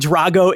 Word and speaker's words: Drago: And Dragi, and Dragi, I'm going to pronounce his name correctly here and Drago is taking steroids and training with high tech equipment.